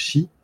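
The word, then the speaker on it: Shi (四): Le 4 étant un chiffre lié au mauvais esprit et à la mort pour le Japon, ça se prononce Shi, comme la mort.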